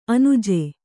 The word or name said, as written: ಅಣ್ಣಾಜಿ